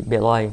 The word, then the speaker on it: Beloi: A-S-H-I-M. – M. M. – Axim. A-S-H-I-M. É. Anota aí para pesquisar. Beloi, B-E-L-O-I. Beloi. – Beloi. Isso.